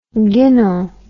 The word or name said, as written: ginnell